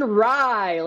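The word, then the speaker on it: Rai: And then, excuse me if I pronounce his last name wrong, is it Aaron Ray to be the top Englishman? Is that where we're going to the prop market? – Aaron Rye, let's get it done this week, baby.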